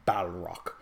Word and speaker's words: Balrog: And the other things you could throw in here, Barlogs, because there were ample amount of Barlogs. Or I'm not saying it right, Balrog.